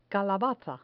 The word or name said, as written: calabaza